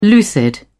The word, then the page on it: lucid: British English